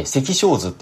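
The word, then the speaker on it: 赤小豆: 赤い小さい豆と書いて赤小図って読むらしいんですが、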